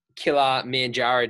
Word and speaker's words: Kilimanjaro: So, and outside of this, so obviously Mount Killer Manjaro. Did I pronounce that correctly? – Yep. – I did.